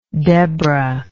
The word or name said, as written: debra